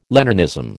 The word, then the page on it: Leninism: American English